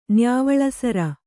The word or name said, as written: ನ್ಯಾವಳಸರ